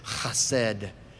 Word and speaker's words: chesed: This word uh, pronounced in the Hebrew is chesed.